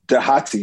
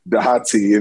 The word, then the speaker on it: Dahati: And the name is pronounced Dahati. You guys actually do a very good job in pronouncing it, Dahati. And, you know, I like to tell people, it's not the cold tea, not the warm tea, but Dahati.